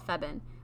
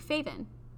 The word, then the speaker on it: Feven: So my name is spelt Feven, but my parents have always called me Faven. – With a V. – Like, the way they pronounce it in my language, and the way my grandma says it, and, like, my aunts and uncles and everybody back home, they say Faven.